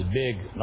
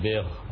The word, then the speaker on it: big: I think all South African presenters pronounce the G like a Va. It's a Gaf, not a Rain. It is big, not big.